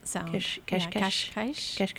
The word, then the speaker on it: Cascais: you told me it's pronounced Kashkesh? – The S. – Is S-H. – Yeah, it's like a sh. – Cascais, Estoril, and that's where it starts, and it's called the Portuguese Riviera.